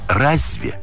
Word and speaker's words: разве: Там, например, «разве» пишется, говорится с мягким знаком, а пишется без мягкого знака.